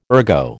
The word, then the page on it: ergo: American English